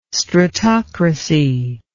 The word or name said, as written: stratocracy